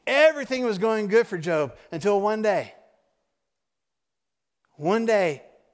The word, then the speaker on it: Job: You see an example of that in the life of Job. If you go back in your Old Testament, there's a book in your Old Testament. It looks like Job, but we pronounce it Job.